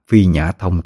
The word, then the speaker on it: Phi Nhã Thông: Huy đọc là Phi Mã Thông, nhưng xin đính chính lại, nó là Phi Nhã Thông thì mới đúng.